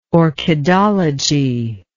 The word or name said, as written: orchidology